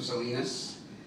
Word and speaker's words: Salinas: Name is Francisco Salinas. Last name is spelled S-A-L-I-N-A-S.